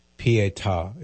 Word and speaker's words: Pietà: P-I-E-T-A. Pietà is how it's pronounced.